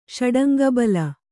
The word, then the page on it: ಷಡಂಗಬಲ: Kannada